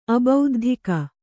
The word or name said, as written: ಅಬೌದ್ಧಿಕ